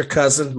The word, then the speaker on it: Couzens: So I'm on Facebook, uh, Victor Couzens.